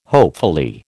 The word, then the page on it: hopefully: American English